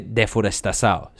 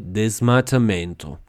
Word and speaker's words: desmatamento: Pues no, no se dice deforestasado. se dice desmatamento.